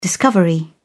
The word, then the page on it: discovery: British English